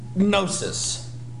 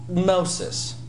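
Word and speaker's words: Gnosis (γνῶσις): They need to buy a vowel between the gamma and the noon, but this is actually a consonantal diphthong, making the sound n. It's pronounced right there, n. Gnosis. Gnosis.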